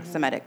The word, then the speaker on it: Semitic: Semitic.